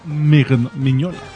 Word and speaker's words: Mignola: Mignola.